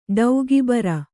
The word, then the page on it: ಟೀಕೆಬರೆ: ṭīke bare